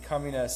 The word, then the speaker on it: Communes: Loci, L-O-C-I, and then Communes, C-O-M-M-U-N-E-S, spelled like communes.